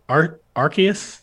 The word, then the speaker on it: Arceus: Is it Arceus? – Yes. – Pokemon Arceus. – I've heard Arceus. Only heard Arceus. – Me too. – I will send you the commercial with the guy saying Arceus on it.